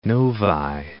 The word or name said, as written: Novi